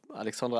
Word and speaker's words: Alexandre: Ähm, der gute Alex Ey, wie spricht man das denn auf Französisch aus? Ich, ich will jetzt nicht Alexander Alexandre sagen. Alexandre äh, würde ich ah, wahrscheinlich. Alexandre sagen. Aya wahrscheinlich. – Alexandre, ja.